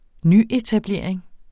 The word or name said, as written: nyetablering